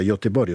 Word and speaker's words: Göteborg: A Göteborg, ma si pronuncia Göteborg, ho scoperto poco fa, si sta costruendo una nuova idea di città europea.